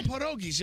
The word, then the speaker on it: pierogies: How about some pierogies? You have some pierogies? – Why do you say it like that? – Can you just... – That's how you say it in Polish. Pierogies.